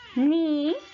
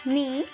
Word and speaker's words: knee: Silent K, la K silenciosa. Knee, rodilla. Knee. Y new, saber.